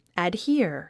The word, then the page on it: adhere: ædhíə r; アドヒア